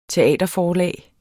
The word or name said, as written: teaterforlag